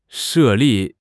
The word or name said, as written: 设立